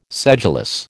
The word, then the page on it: sedulous: American English